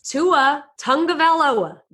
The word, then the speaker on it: Tagovailoa: And he actually said you could say it like this. Tua Tagovailoa.